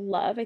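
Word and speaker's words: Lauv: There's an artist called Love. I think that's how he pronounces it. It's L A U V.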